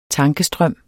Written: tankestrøm

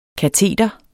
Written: kateter

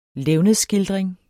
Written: levnedsskildring

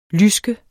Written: lyske

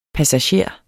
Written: passager

